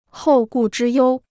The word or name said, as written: 后顾之忧